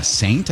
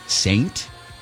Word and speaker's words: S8NT: Saint, I think it is. S-8-N-T. Saint? – Wow. – Isn't that what it'd be?